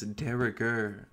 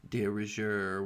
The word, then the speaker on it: de rigueur: So I just looked up how to pronounce it, and it's de rigueur, apparently, not de rigueur, whatever the hell it is that I just said.